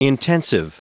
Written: intensive

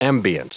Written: ambient

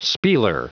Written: spieler

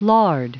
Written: lard

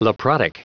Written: leprotic

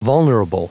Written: vulnerable

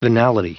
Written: venality

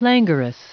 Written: languorous

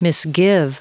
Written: misgive

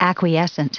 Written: acquiescent

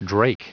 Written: drake

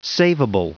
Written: saveable

